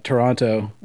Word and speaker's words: Toronto: So since we have someone from Toronto, I know the second T is silent.